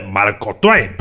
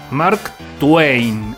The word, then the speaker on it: Mark Twain: Clemens, más conocido universalmente por su seudónimo de Marco Twain y después agregaba la pronunciación con la que cada lector debía leer ese apellido. Mark Twain, así con E antes de la letra I.